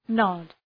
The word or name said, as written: nod